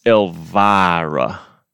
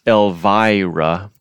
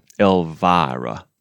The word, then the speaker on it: Elvira: It's Elvira. Not Elvira, for example. Elvira.